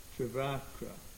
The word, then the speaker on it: Trivakra: Trivikra. But isn't it, it's a short A in the middle one, right? Trivakra, right?